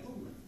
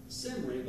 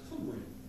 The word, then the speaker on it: Cymry: In history books you see Qumri spelled K-H-U-M-R-I or Y. I think it's I. And then to the Welsh, though, they spell Qumri C-Y-M-R-Y. And they say it exactly the same way, Qumri. If you're looking at the word, you might think it's pronounced Simri, but it's pronounced Qumri.